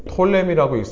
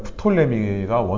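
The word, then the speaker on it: Ptolemy: 그 다음에 이제 요 밑에 보시면 이렇게 홍해가 있고 시내 반도가 있는데 이집트 지역 원래는 이제 이집트로에서부터 팔레스타인 지역을 전부 다스렸던 왕이 프톨레미 영어로는 이제 톨레미라고 읽습니다. 근데 이제 원어상으로 말하면 프톨레미가 원어적인 발음이라서 이제 한국말로는 프톨렘이라고 하고요. 영어는 톨렘이라고 하죠. 그 다음에 이 알렉산더가 페르시아를 정복하면서 얻은 바벨론과 페르시아 땅, 이 전체에 있는 땅들을 누가 다스리냐면 어, 셀루커스, 셀루커스라는 왕이 다스리죠.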